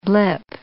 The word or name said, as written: blip